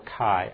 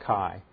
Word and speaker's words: chi: or chi. I always pronounce that chi, because pi is P-I and chi is C-H-I. I pronounce it chi, but I think chi is probably correct.